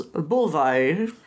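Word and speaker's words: Buliwyf: Hörger also introduces them to one of the king's sons, Bulvaid. That's how they say it in the movie.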